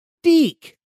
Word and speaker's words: DIC: It is spelled D I C Entertainment. Now, how would you pronounce that? Deek. Okay. I too have always called it Deek because of that little ending credit scene where the moon is outside the little kid's window.